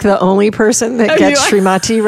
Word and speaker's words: Srimati: Is that Srimati? – Srimati. Thank you for saying it right. – Yes, aka Julie Pratt. – Pyatt. P-I-A-T-T, yeah.